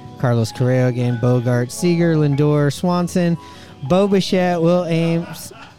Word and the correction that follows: Adames: How do I say his name? Is it Will Adam? The guy that's on Milwaukee? – Oh, Will Adames.